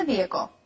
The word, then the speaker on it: vehicle: And to our English students out there, this word is spelled V-E-H-I-C-L-E. But we don't say the word or the H sound. Some people say it, others don't.